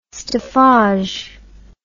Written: staffage

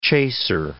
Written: chaser

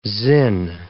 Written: zin

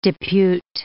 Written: depute

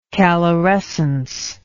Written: calorescence

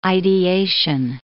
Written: ideation

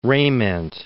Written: Rejment

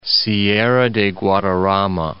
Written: Sierra de Guadarrama

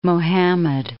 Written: Muhammad XI

